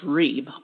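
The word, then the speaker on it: reeb (ריב): And it's R-I-B. It looks like rib, but it's pronounced reeb, R-E-E-B.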